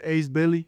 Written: Ace Bailey

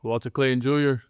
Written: Walter Clayton Jr

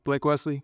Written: Blake Wesley

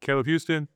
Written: Caleb Houstan

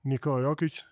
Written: Nikola Jokic

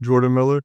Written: Jordan Miller

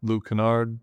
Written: Luke Kennard

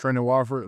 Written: Trendon Watford

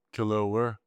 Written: kelel WARE

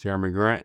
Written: Jerami Grant

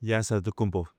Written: Giannis Antetokounmpo

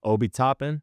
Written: Obi Toppin

